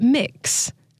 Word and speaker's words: Mx: And it's so funny because they pronounce it mix.